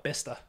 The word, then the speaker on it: Bicester: Wait till you see how they spell Bister. – That sounds dirty, bro. – Bicester.